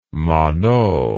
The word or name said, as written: monod